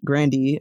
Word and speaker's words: Grande: I say this as a noted Mocha Grande. I can definitely see her faults, even her last name actually being pronounced Grandy instead of Grande, but choosing to go with that pronunciation for a public persona in order to seem a little bit, mm-hmm, in order to seem a little bit more racially ambiguous.